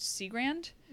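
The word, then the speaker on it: Cigrand: Other early victims may have included Minnie and Nanny Williams around the time of the Chicago World Fair, or Emmeline, I believe it's pronounced Seagrand.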